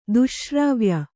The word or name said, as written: ದುಶ್ಶ್ರಾವ್ಯ